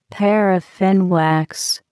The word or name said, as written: paraffin wax